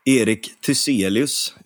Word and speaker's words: Erik Thyselius: Erik Thyselius, uttalar jag det rätt? Ja, men det tycker jag.